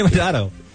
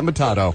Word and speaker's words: Matteo: Everyone goes around the room and names something they know about Drea Di Matteo. – Including how to say her last name. – Right. – Which is Matteo. – All right. – Not Matado. That's Hakuna Matado.